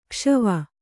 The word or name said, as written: ಕುಶವ